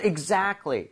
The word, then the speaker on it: exactly: So exactly 이렇게 얘기를 할 때 제가 발음하는 것만 들어보셔도 알겠지만 T 사운드가 거의 안 납니다. C하고 L이라는 자음 사이에 sandwich가 돼서 거의 안 나요.